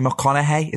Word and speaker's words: McConaughey: And then we've got, I can't say his name, Matthew McConaughey.